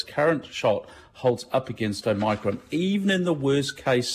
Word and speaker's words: Omicron: Omicron is how the classicists pronounce.